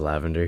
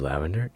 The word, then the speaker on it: Lqvandr: It is Lavender here, and that is how you pronounce my name, Lqvandr. It's spelled weird, I know, but this is Lavender, and this is a trailer for my podcast, which is called Life As It Is.